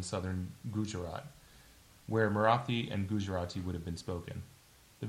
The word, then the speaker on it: Gujarati: As I said, it comes from two different versions of toddy, T-A-D-I, and tari. One is from the Marathi region, and one is from, I'm going to just butcher this, but Gujarati? – Yeah.